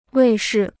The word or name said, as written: 卫视